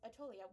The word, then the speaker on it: Aetolia: Aetolia, which I'm probably butchering the pronunciation, but I feel like Aetolia sounds right. – Does it? – It sounds right to me.